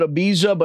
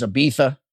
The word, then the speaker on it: Ibiza: Most people go to, I always called it Ibiza, but apparently the proper pronunciation is Ibiza. I don't know how a Z into a TH, but whatever.